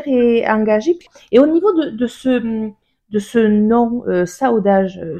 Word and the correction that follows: Saudade: si je prononce bien, c'est bien comme ça que ça se prononce. – Oui, c'est ça, c'est Saudade.